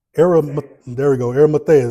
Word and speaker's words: Arimathea: It says, after these things, Joseph of, I don't know how to say it, Arimathea, is that right? Thank you, my brother.